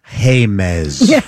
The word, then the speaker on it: James: My name is spelled James, but please pronounce it Hamez.